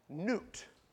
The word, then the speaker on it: Nut: Now, there's a God of the air who is called Nut. It's a goddess. You can look it up. It is spelt N-U-T, but pronounced N-E-W-T.